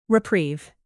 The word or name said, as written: reprieve